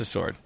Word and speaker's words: sword: No, it's a sword. – Then what the hell are you pronouncing the W for?